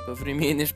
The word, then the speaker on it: повременишь: А, понятно, что тебе хочется новые кроссовки прямо сейчас, но если ты немного повмернишь повременишь, ужасное произношение, повмернишь Повременишь? повременишь, хорошо, повременишь, это, это как да, э, немножко ждать и потом а, делаешь что-то, да.